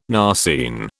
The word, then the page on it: narceine: British English